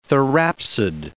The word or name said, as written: therapsid